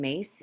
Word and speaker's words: Myss: And then the other one I would say is Carolyn Mace, and it's spelled M-Y-S-S, so people think it's Miss, but she wrote the best-selling book, Anatomy of the Spirit, Sacred Contracts.